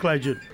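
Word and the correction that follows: Kledir: Cleito e Cledir, cara.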